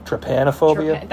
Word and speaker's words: trypanophobia: Trypanophobia. – I would guess it would be like trypanophobia. – That sounds a little better.